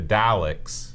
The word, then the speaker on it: Daleks: The Daleks. Is that right? – That's pretty good, yeah. – Okay. It's not Dalek.